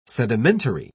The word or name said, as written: sedimentary